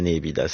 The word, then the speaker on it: nepita: Mais nous, on dira c'est la nébida. Ça ne ressemble à rien d'autre. – Ça s'écrit N-E-P-I-T-A.